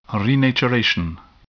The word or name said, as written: renaturation